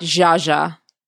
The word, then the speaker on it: Zaza: But my Facebook page is Zaza Ali, Z-A-Z-A, not Zaza. Y'all know y'all be playing when y'all be mispronouncing my word. My name is Zaza, Z-A-Z-A, and then Ali.